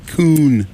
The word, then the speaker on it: Kuhn: I don't know how to pronounce it. – Kuhn. K-U-H-N? – Yeah.